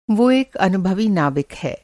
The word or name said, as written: नाविक